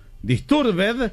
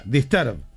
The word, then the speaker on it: Disturbed: No hay ninguna palabra en inglés que al final se pronuncie ed. Disturbed se pronuncia disturb.